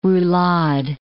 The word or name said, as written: roulade